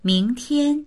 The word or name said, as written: ming2tian1